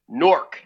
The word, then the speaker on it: Newark: And we pronounce it over here in Jersey, Newark. – Yeah. – N-O-R-K. Not the correct spelling.